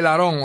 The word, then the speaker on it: Laron: Se llama el síndrome de Laron, Laron, Laron, así como lo estoy pronunciando.